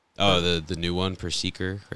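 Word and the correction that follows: Perrserker: It's Perserker, but Perserker. – I didn't play him. – I prefer to pronounce it Perskerer. – Perskerer. – Because that was how I read it the first. I was like, Perskerer? And it kind of just stuck with me.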